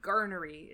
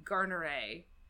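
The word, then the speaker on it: Garneray: These are engravings based on paintings by, he calls him Garnery. It's actually Ambroise Louis Garneret, like with an A in that last syllable.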